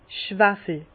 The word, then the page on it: Schwawel: Alsatian; Bas-Rhin; Herrlisheim